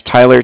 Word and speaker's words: Tyler: Yeah, you could put down, my name was Tyler, T-Y-L-E-R. – Okay. – And Cardiff is spelt C-A-R-D-I-F-F, as in Frank, Frank.